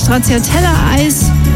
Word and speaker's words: Stracciatella: Und das Stracciatella-Eis, ja, so wird es ausgesprochen, kommt vom italienischen Wort stracciare.